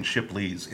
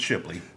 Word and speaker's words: Shipley: I keep saying Shipley's. It's Shipley.